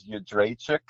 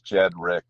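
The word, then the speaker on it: Jędrzejczyk: So, well, the real way to pronounce it is Jedrzejczyk, and Oh. – that the two Eastern European teachers, they knew how to pronounce it, and like I kind of like got excited, but that's pretty silly. So anyways, my dad, who was born in Poland, he Americanized it, but it's kind of silly. It's like it changed that the 10 letters to just two syllables, Jed Rick, but I don't know.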